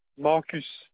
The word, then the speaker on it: Marcus: S-A-H-A. – D'accord. Le prénom? – Marcus, M-A-R-C-U-S. – D'accord. Et l'adresse? – Impasse Maurice Parter, P-A-R-T-E-R.